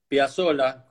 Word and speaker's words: Piazzolla: Piazzola, como en Italia. – La doble Z y la doble L son como una S estirada, Piazzola o Piazzola.